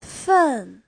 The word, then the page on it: fen: fen4